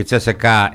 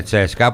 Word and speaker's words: Τσέσκα: Η Τσέσεκα έγινε Τσέσκα, που είναι και το σωστό. Ίσως είναι ένα από τα λίγα σωστά. Γιατί έτσι το προφέρανε πάντοτε οι Ανατολικοί, Τσέσκα.